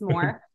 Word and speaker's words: Moore: Hi, my name is Jen Moore. I have a very boring last name. It's just Moore, one syllable, very common.